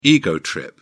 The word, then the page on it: ego trip: British English